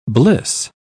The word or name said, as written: bliss